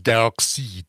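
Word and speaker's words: Darkseid: A French guy just told us it's pronounced Dark Seed.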